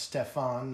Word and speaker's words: Stephan: Even as a young boy, Stephan Leder, and that's spelled S-T-E-P-H-A-N, so I assume it's pronounced Stefan, not Steven.